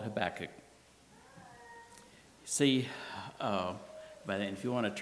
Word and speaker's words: Habakkuk: you pronounce it like tobacco with a K, Habakkuk," and that helped me remember that.